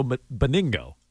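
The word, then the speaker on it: Benigno: Say his name again. – Bedingo? No. – That's not it. – Joe Bedingo? – Joe Beningo.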